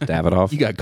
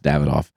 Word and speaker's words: Davidoff: So I say Davidoff a lot, and I know it's pronounced Davidoff. – You got called out for this. – I got called out about it one time on Instagram. So I just wanted to say, I know a minute ago I said Davidoff Flake Medallions, and I know it's Davidoff.